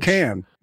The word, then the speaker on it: Cannes: I would say it's probably pronounced Cannes. – Cannes. It's con. – Word. – K-A-N. Can. Can. Wait, so how do you fucking, so con or can? – Yeah, it is can. – Can. – K-A-N.